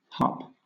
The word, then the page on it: harp: British English